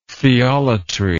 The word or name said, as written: theolatry